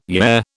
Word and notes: yeah: British English

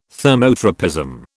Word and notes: thermotropism: British English

